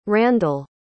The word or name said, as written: Randle